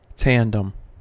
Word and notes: tandem: American English

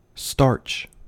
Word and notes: starch: American English

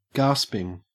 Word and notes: gasping: Australian English

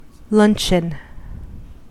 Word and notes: luncheon: American English

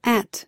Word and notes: at: American English

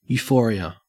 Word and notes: euphoria: Australian English

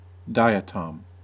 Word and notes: diatom: American English

